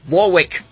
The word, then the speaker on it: Warwick: We have the same with I love the way, because we have a place in the UK called Warwick. Okay, spelled Warwick, but we pronounce it as Warwick.